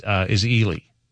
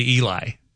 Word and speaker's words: Ely: Another one they get wrong in Minnesota is Ely. They say Eli oftentimes. So that one, if you know, it's just you're like, well, that just gets your attention.